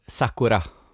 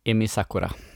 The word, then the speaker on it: Sakura: Jag, jag tror att jag uttalade hennes fel. Su Sukuru? – Sakura. – Sakura. – Emi Sakura.